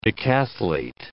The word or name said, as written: decathlete